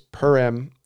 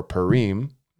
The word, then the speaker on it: Purim: But it's either, there's a few different ways that are acceptable to pronounce it. It's Purim or Purim or Purim or Purim.